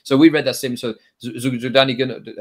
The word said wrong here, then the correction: Zyuganov: uh, always get his name wrong, the leader of the Russian Communist Party, mm-hmm. um, Zyuganov, he.